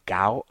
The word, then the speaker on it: Goux: Goux, Goux, maybe? Is that how you pronounce it? A silent X at the end?